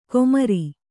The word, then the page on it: ಗೋಮಾರಿ: gōmāri